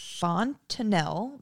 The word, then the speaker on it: fontanelle: Also, like humans, the fontanelle begins to close as the dog matures.